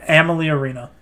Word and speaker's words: Amalie Arena: how do you pronounce it? – Amalie Arena. – Amalie Arena.